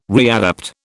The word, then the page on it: readapt: British English